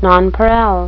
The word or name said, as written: nonpareil